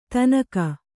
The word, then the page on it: ಥಣ್ಣಗೆ: thaṇṇage; kannada